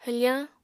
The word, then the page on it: hwfañ: apatani